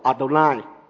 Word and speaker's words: Adonai: 他就用了另一个字，主的字来代替这个名字。所以他的读音我们通常读 Adonai。